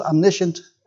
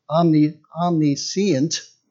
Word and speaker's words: omniscient: You know, I thought it was omniscient as well. And then I heard it's pronounced with four syllables, omniscient. So I think I'll go with whatever rolls off my tongue at the moment.